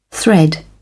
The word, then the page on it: thread: British English